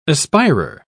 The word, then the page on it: aspirer: British English